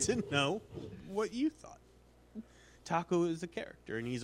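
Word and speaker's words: THAC0: It's taco. You just pronounce that taco.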